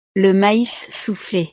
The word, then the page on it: le mais souffle: frances